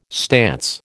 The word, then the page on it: stance: American English